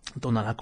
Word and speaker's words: Tonalá: Tonalá con acento en la última A, tal cual como se escribe,